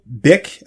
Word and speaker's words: Byck: So this guy, Samuel Bick, the last name is spelled B-Y-C-K.